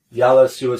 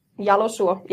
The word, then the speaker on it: Jalosuo: So you say it Jalosuo. – Jalosuo. So I have Jalosuo. Jalosuo. – Jalosuo. Yep.